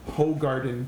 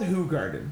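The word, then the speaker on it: Hoegaarden: Uh, I'm gonna call it what I think it's called, Ho Garden Beer. It's called Who Garden, but it's spelled Ho Garden.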